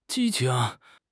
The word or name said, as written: 激情